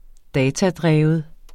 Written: datadreven

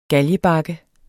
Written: GALGEBAKKE

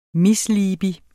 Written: misliebig

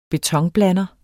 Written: betonblander

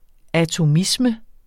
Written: ATOMISME

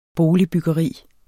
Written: boligbyggeri

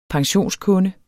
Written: pensionskunde

